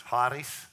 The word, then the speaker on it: charis: The Greek in that instance for grace, it's spelled C-H-A-R-I-S. And I've heard two pronunciations, charis and charis. Charis. You got to really get that. You got to get that hawk at charis.